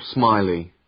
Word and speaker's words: Smiley: Uh, Paul Smiley. – How do you spell that? – S M I L-E-Y.